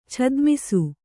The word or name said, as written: ಛದ್ಮಿಸು